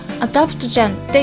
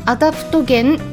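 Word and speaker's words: adaptogen: 皆さんはアダプトゲンという言葉聞いたことありますかね英語ではアダプトジェンって呼んでるのでちょっと発音が違うんですけれども、まあ、日本語でもカタカナでアダプトゲンって検索したら情報がいろいろと出てくるかと思うんですよね。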